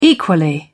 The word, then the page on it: equally: British English